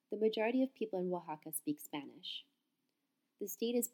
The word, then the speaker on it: Oaxaca: Oaxaca, Mexico, by Cindy Pitney. Basic information. Oaxaca, pronounced Wa-ha-ka, has been a state since 1824.